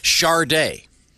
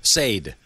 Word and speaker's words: Sade: The song's even funnier if you picture an alligator in, like, sunglasses sort of leering at Charday. – Yeah. – Or as I like to call her, Sade. – Yeah, that's a... – Farsade.